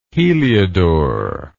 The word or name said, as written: heliodor